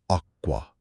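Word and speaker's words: acqua: آب به ایتالیایی میشه اکوا و تلفظش هست آکوا.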